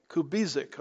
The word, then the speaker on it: Kubizek: Now we have another name, August Kubizek. Does that sound right? How do you say that? – Kubizek. – Okay. I mangled a few syllables there.